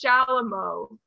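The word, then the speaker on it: chalumeau: It's Shalamo. – With an S or an R a C? – A C-H.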